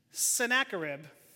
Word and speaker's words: Sennacherib: Let's see if I can pronounce his name right. Sennacherib. Yes, I got the nod from Malcolm.